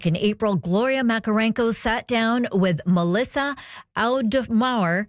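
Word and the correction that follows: Auf der Maur: I'm pronouncing that completely wrong. That's Melissa Audemauer, a Montreal musician and former bassist for Hole and Smashing Pumpkins.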